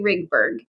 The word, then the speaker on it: Rigberg: But did I say your last name right or did my accent best me? – Absolutely. Carrie Rigberg. Yes.